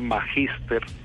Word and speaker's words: magíster: Hoy, por ejemplo, en Colombia enviamos una que, que, que aclara cómo se escribe magíster y cómo se pronuncia, que es como lo acabo de decir,